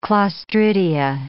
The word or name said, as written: clostridia